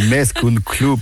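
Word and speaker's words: Més que un club: Oui, Messe que un club, c'est ça? Je l'ai bien prononcé. – Bravo pour l'accent. Messe que un club.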